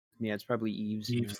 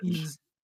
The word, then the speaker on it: Yves: Yeah, it's probably Yves. – He's French. Yves.